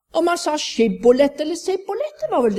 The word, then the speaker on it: Shibboleth: Da sa de til ham, si Shibboleth. Men han sa Sibboleth, for han kunne ikke uttale ordet riktig. Så grep de ham og slo ham i hjel ved Jordans svadesteder.